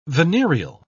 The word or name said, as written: venereal